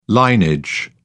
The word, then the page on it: linage: British English